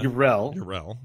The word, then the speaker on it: Yrel: It's all because he was trying to say is Urel. – Yeah. – Urel.